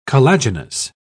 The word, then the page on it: collagenous: British English